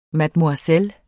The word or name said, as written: mademoiselle